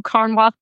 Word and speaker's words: Carnwath: I'm also going to spell Carnwath because I realize it sounds different than what it looks like. So Carnwath is spelled C-A-R-N-W-A-T-H.